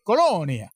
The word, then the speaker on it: Colonia: Colonia. – Yeah. – That's how it's pronounced.